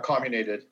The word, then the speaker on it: comminuted: Yeah, comminuted.